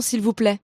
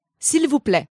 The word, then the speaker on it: s'il vous plaît: Un croissant, s'il vous plaît. – A croissant, please. Okay, I think I got the first part. But what was the pronunciation for please? – S'il vous plaît.